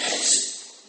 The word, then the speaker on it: hesed: But the Hebrew term is hesed. You got to, you really said it right, you have to almost spit on your neighbor. H-E-S-E-D, hesed. That's what he said in Hebrew. That had that accent, the tongue roll.